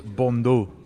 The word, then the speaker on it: Bandeau: I think it's pronounced Bondo, and I'm a, a keen lover of the headband myself.